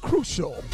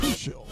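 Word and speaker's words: Krewcial: I'm gonna spell it for you. The group was called Crucial. Each and every day, each night. K-R-E-W, crew. C-I-A-L. That's a little bit different, okay? Crucial.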